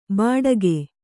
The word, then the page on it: ಬಾಡಗೆ: bāḍage